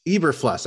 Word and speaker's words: Eberflus: They hired Eberflus. Is that how we pronounce the name? – Eberflus.